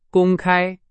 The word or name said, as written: 公开